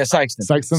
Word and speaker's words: Sykeston: Sykeston? – Yeah, Sykeston.